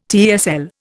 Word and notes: TESL: Indian

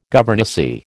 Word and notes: governessy: American English

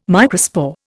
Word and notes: microspore: Indian

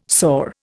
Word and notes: sore: Indian

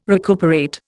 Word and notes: recuperate: Indian

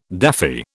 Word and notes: daffy: British English

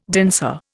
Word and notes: danseur: Indian

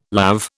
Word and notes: lav: British English

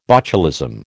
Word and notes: botulism: American English

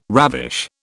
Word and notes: ravish: British English

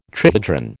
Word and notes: trihedron: American English

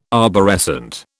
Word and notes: arborescent: British English

